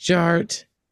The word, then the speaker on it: DJ Art: And my name is Jared, aka DJ Art, with two T's for a double dose of that tink tink. The D is silent, so it's just Jart.